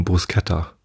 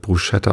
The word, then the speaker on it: Bruschetta: Und viele sprechen es deshalb Bruschetta aus, weil SCH ist halt, ne? Ich glaube, die korrekte Aussprache ist Bruschetta.